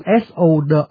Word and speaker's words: sword: tuy viết sword nhưng mà đọc bỏ chữ w không đọc, đọc là sword.